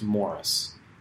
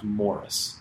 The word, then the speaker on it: Maurice: Oh, the character's name was Morris, but the weird thing is that British people pronounce the name Maurice as Morris. So his name is spelled M-A-U-R-I-C-E, but it's pronounced Morris.